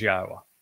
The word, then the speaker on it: Yahweh: God's one and only name is Yahweh, that this is how it's pronounced.